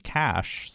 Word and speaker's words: cache: Same with cash, C-A-C-H-E. – True, exactly. Well, I mean, with cash, the E is silent, and then with resume, the E is pronounced, too, exactly.